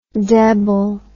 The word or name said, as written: debile